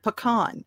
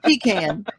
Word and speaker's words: pecan: And see, I'm constantly telling people how to pronounce the word pecan. I go, no, it's not pecan. It's pecan. You're the source.